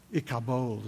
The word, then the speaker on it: Ichabod: Ichabod. Ichabod is how you pronounce it.